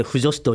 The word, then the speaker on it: 腐女子: で特に女の子は腐った女子と書いて腐女子と読みますけど、